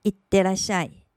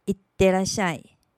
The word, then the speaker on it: いってらっしゃい: 这个句子里有两个促音，分别在い和て以及ら和し之间，可能有点不好发音。我们再来多练几次。いってらっしゃい。いってらっしゃい。